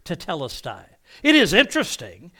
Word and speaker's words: tetelestai: And that's, and by the way, that's how it's pronounced. I was listening to one guy, and he was tetelestai. No, it's tetelestai. If you're going to use the Greek, at least pronounce it correctly.